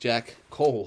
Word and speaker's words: Jack Cole: you gotta really pronounce his name or else it sounds like you're saying Jack Hole. That's why I was like, Jack Cole.